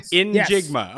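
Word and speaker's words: Njigba: In Jigba? Am I saying that correctly? – In Jigba.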